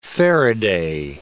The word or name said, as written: faraday